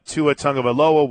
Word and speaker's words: Tua Tagovailoa: What we don't know is whether or not Tua Tagovailoa will play.